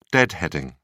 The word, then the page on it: deadheading: British English